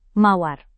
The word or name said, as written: mawar